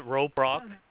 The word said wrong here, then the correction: Robla: R-O-B-L-A, Robla Community Park.